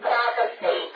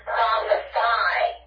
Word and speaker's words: prophesy: Everybody saying the word is prophecy. The word should be pronounced prophesy.